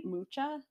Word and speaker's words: Mucha: Am I saying that right? Mucha? – Yeah.